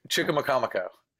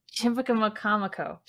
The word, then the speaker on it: Chicamacomico: Okay, I'm gonna try to pronounce it again. Chicka Chickamakamiko Chickamakamiko. – Chick- Chicamacomico. – Chicamacomico. – I have to think, of, think of the word comic.